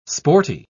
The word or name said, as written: sporty